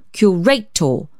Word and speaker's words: curator: At last he broke out, "Mr. Erskine, we're in the habit in this country of saying curator, following the analogy of the Latin, in which, as you are doubtless aware, the penultimate syllable is long."